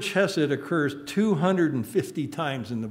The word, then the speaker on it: chesed: Hebrew word in English is spelled C-H-E-S-E-D, chesed, C-H-E-S-E-D. And it means kindness, mercy, loving kindness, unfailing love, tenderness, and faithfulness.